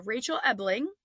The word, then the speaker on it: Ebeling: With me today is Rachel Ebeling.